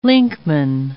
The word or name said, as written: linkman